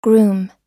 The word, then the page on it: groom: American English